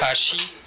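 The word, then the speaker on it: hashi (箸): J'espère que vous avez bien entendu la différence de prononciation. Il y en a un qui est en fait descendant, donc le hashi, il y a une descente de niveau, et l'autre est une montée de niveau, hashi.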